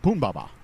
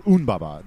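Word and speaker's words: Humbaba: Pumbaba, or Unbaba, there's a couple of different ways to pronounce it, shows up as well.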